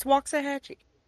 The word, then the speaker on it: Waxahachie: It's Waxahachie. That's how I say it.